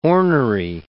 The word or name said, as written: ornery